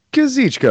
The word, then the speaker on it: Kazuchika: Kazuchika Okada, please and thank you.